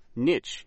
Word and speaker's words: niche: Niche is spelled N-I-C-H-E, niche.